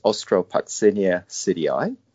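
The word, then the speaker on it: Austropuccinia psidii: In the English-speaking world, generally it's pronounced as Ostrococcinia sidii.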